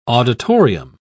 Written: auditorium

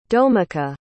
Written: domeka

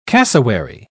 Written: cassowary

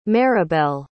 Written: maribel

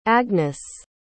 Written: agnes

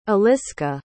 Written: eliska

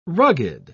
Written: rugged

